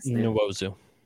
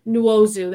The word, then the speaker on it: Nwosu: How do I say that? Nwosu. – Nwosu, there we go.